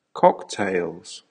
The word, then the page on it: cocktails: American English